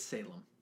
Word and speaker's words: Salem: In the audio version, it's Salem. – It's Salem? Like, it's like Salem, Massachusetts.